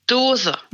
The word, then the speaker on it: Dose: Hej, sa jag ditt efternamn rätt nu? Eller hur ska vi uttala det? – Ja, Dose. – Dose. Ja, men bra. Då har vi koll på det.